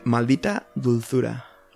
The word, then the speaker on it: Maldita Dulzura: Maldita Dulzura.